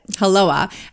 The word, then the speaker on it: hulloa: And it's spelled H-U-L-L-O-A, but halloa.